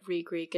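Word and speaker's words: Reek: Re-Greek, it rhymes with freak or leak or meek or insert X eek thing here.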